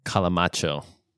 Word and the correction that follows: Calimocho: Marilyn, can you give us a pronunciation? – Calimocho. – Of that? – Calimocho. Mocho. But, you know, we did a little twist on it, made it a little fancier because we're fancy.